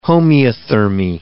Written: homeothermy

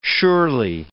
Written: surely

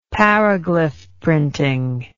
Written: paraglyph printing

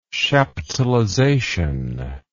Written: chaptalization

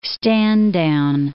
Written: stand-down

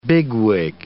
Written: bigwig

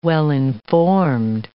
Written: well-informed

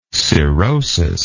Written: cirrhosed

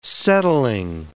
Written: settling

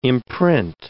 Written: imprint

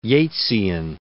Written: Yeatsian

